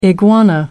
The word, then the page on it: iguana: American English